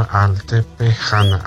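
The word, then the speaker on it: Altepexana: Te pidieron un tema de la Sonora Altepejana, así se pronuncia.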